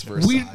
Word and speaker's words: Versailles: That's the French in Paris. It's Versailles. – We don't live in France. We live in fucking Pennsylvania. – The word is fucking French, so you pronounce it French.